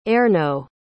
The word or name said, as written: Erno